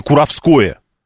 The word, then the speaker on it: Куровское: это поселение Куровское. Так правильно говорить, а не Куровская, как я ошибочно сказал.